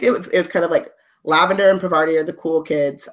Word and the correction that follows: Parvati: I'm saying that name wrong. – Yes. Oh, for everyone listening, you can see my pinned tweet, but it's Parvati. So the first syllable is the strong one.